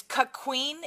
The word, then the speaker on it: cuckquean: So it's, yeah, it's cuck queen.